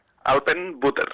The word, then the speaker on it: Alpenbutter: Bueno, ¿cómo se pronuncia exactamente el nombre de este grupo? – Alpenbutter. – Vale, es más alemán que inglés, claro. – Exactamente, es alemán, sí.